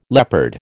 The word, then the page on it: leopard: American English